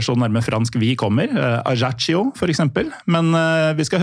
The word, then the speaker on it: Ajaccio: Ja, sånn I tilfelle folk lurte på om vi litt dårlige I språk, så det sånn vi uttaler Ajaccio.